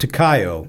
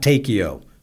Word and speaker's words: Takeo: "Colonel, it's pronounced Takayo, not Takeo.